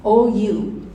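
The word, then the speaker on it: ou (οὐ): The Greek word is ou. It's my favorite word so far. Ou. O-U, pronounced ou.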